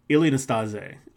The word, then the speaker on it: Ilie Năstase: It's, I believe I'm pronouncing this correctly, Ilie Năstase, that's how you pronounce it.